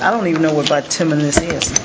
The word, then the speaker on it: bituminous: Bituminous.